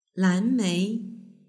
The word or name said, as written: lanmei